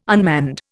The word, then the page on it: unmanned: Indian